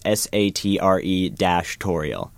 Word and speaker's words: sartorial: And I'm not mispronouncing Sartorial. It's Sartre-Torial.